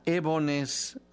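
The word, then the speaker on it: Eboness: Y la canción se llama Ebones, así como lo pronuncio, Ebones, con B grande y con doble S al final.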